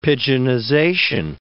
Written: pidginization

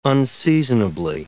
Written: unseasonably

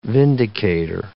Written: vindicator

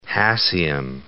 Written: hassium

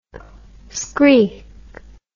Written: screigh

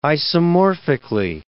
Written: isomorphically

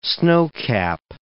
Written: snowcap

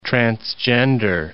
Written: transgender

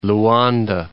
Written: Luanda

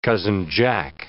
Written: Cousin Jack

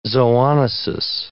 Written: zoonosis

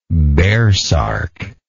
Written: baresark

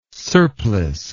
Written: surplice

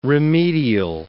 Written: remedial

